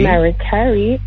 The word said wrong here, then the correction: Mariah Carey: Always Will Be My Baby. – Say the artist again. – Mariah Carey. – There we go. Yes. Okay. Perfect. I got to make sure you say it correctly.